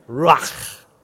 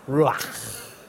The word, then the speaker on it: Ruach: And now, I'm no expert at Hebrew pronunciation, but you've got to get a good guttural going. Ruach. Have a go at that. Ruach.